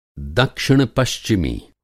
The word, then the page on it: दक्षिण-पश्चिमी: Hindi